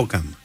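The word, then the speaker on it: Hokum: Είναι το Χόκουμ ή Χοκούμ ή κάτι τέτοιο. – Χόκαμ. Ναι. – Χόκαμ, συγγνώμη.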